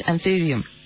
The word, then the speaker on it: Anthurium: And so is the anthurium. Is that how you say it? Anthurium? – Anthurium, yes.